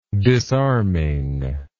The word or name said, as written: disarming